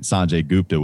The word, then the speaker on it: Sanjay Gupta: I thought CNN medical correspondent Sanjay Gupta, which is what I think is the pronunciation of his name.